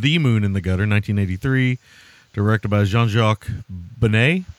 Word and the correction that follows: Beineix: Benet, is that how you say his name? – You would think, but it's Benex.